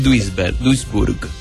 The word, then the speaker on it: Duisburg: Pero hay, cerca de Düsseldorf, hay un pueblito que se llama Duisberg, algo así, donde apareció otro señor y me invitó a su casa también. Pero este es peor en realidad que el anterior. Esto es Duisburg.